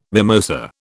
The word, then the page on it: mimosa: British English